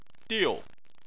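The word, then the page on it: diu: tone 4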